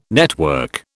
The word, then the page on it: network: British English